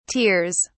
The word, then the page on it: tears: British English